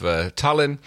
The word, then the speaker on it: Tallinn: A lovely little gunboat diplomat by the name of Tallinn. – Very good. I love how you pronounce it like the capital of Estonia.